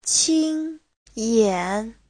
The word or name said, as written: qin1yan3